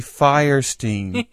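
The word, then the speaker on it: Fierstein: Number three, it's Harvey Firestein, pronounced like he won't fly Lufthansa.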